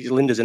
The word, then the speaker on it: Lijnders: Eh, Lijnders in there. Why am I saying whatever he's called? That's exactly how you pronounce his name.